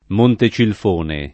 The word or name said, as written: Montecilfone